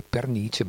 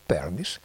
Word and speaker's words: Pernice: No, non ho bevuto, però c'è questa così divertente, come dire, sequenza, perché come dicevo prima, se avessi dovuto pronunciare in italiano il cognome sarebbe Pernice, ma qui sono americani, diciamo Pernice, come invece i Felice Brothers diventano Felice Brothers,